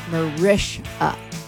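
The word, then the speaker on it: Mariska Hargitay: It's going to be hosted by Mariska Hegarty, who said the other day in an interview that everybody mispronounces her name and they call her Mariska Hegarty. And it's Mariska. Mariska Hegarty.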